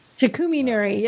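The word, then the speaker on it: Takuminuri: It's the am I saying that right? Takumi Inari? It looks like that. Artisan red, I can say, but I don't. – Takumi Inari. – Yeah, that sounds right.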